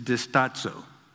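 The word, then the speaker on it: distazo (διστάζω): That specific word in the Greek is distazo. That's how they say to pronounce it.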